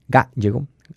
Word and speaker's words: Gállego: Gallego, atención también porque tiene un acento en la primera A, por lo tanto es una palabra esdrújula.